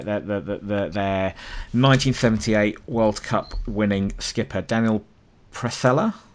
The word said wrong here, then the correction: Passarella: I think. – Passarella. – Passarella. Okay, Passarella.